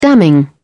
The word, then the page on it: damning: British English